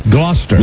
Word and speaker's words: Gloucester: They're in, forgive me if I mispronounce this, Gloucester, England? – Gloucester. – Gloucester? – Gloucester. – Gloucester. – Gloucester. There we go.